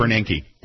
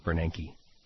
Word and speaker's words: Bernanke: Bernanke. – Thank you. – Bernanke.